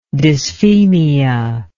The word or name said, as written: dysphemia